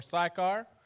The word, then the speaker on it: Sychar: As you can see, Sychar is right here, or Sychar, if you want to pronounce it that way.